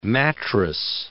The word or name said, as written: mattress